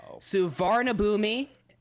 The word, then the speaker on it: Suvarnabhumi: He was caught smuggling the live animals in his pants at, I'm sure I'm pronouncing this right, Suvarnabhumi Airport in Bangkok.